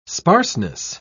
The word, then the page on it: sparseness: British English